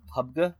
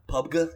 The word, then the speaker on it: PUBG: Played a couple ARAMs, and then other than that, I'm also playing some pub, and I'm also pretty terrible at it, but I certainly have a lot more kills than Andy, apparently. – I believe it's pronounced PUBG. – PUBG, yeah.